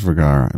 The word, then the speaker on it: Vergara: Not even close. Vergara. I think it is Vergara. V E R G A R A. Vergara, Sofia Vergara.